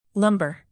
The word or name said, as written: lumber